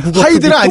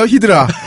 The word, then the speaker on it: 히드라: 하이드라 아니에요 히드라. 릭 퓨리. – 네. 아, 당연히 디아블로에서도 법사가 히드라 쓰지 하이드라 쓰지 않거든요.